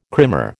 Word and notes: krimmer: American English

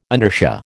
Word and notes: inertia: American English

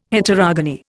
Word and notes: Heterogony: Indian

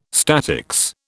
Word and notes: statics: British English

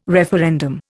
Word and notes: referendum: Indian

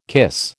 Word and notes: cuisse: American English

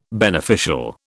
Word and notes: beneficial: British English

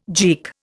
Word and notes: geek: Indian

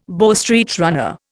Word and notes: Bow Street runner: Indian

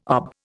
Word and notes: OB: Indian

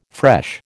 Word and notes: fresh: American English

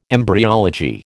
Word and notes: embryology: American English

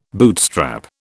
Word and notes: bootstrap: British English

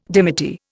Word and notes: dimity: Indian

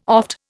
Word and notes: oft: Indian

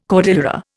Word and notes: cordillera: Indian

